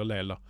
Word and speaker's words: Laysla: Det stammer, staves ikke Leila, men jeg uttaler det som Leila, for det staves L-A-Y-S-L-A. Jeg vet ikke hvordan disse meksikanerne staver sånne ting, men vi sier Leila.